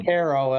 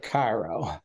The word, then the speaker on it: Cairo: It's like Cairo, Illinois instead of Cairo.